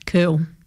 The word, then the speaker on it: Kerle: Nah, no. K E R L E. K E W E L, that's soccer player. Kell. K E R L E. How would you say that? – Kell.